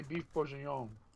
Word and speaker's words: bourguignon: How do you say it? – She's trying to say beef bourguignon.